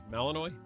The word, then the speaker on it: Malinois: Men are more likely than women to like the Belgian, how's it pronounced? Belgian Malinois? Malinois? – Mm-hmm, sure. – Don't know. – You're saying you don't know. – I've never heard of that. – Yeah. – Mm-hmm. She's just being supportive. – Don't try to take credit for it. Yeah, that's it. – Yep. I heard that 1,000 times, and you said it right, my man.